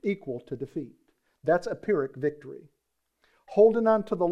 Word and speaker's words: Pyrrhic: Friends, holding on to the law after you come to Christ, listen to me very carefully, is a Pyrrhic victory. Now, there's a word you don't use very often. Let me spell it for you. P-Y-R-R-H-I-C, Pyrrhic victory.